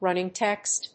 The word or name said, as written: running text